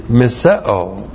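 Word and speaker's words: Miseo (μισέω): And that word for hate is miseo. M-I-S-E long O. Miseo.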